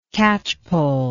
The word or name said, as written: catchpollery